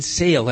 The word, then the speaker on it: sale: When they're well off the tourist trail, they'll see that word sale all the time, but it really doesn't mean sale at all. And though the letters are exactly the same as the word sail in English, they're pronounced sale in Spanish, sale, which means go, get out, or leave in English.